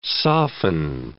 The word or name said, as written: soften